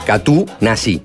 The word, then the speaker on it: Katoennatie: Y preguntarle personalmente cómo se pronuncia Katoennatie. – Claro. – Que es así como se escribe, Katoen Koka Natie. Y le dijo, Katunasi. Y bueno, listo, ya está, es Katunasi.